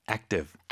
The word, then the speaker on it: active: 근데 active 뒤에 v까지 발음하지 않습니다. – active. – active. – 아주 짧게 v. v. – 어. – active. – active. – active. – 어. 그 v라고 하고 싶겠지만 거기서 딱 끊어서 active.